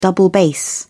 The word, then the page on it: double bass: British English